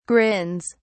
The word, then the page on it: grins: British English